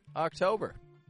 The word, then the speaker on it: October: And those are 31 opportunities to master the pronunciation of the month October. So, how do we pronounce this? It begins with a short O sound.